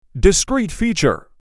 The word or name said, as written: Discrete feature